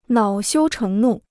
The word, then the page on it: 恼羞成怒: nǎo xiū chéng nù